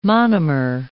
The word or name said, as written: monomer